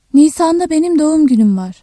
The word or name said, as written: Nisan